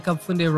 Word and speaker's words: Kapfunde: People never get the Kapfunde wrong.